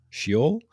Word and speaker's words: Sheol: The band's album Sheol will be released on...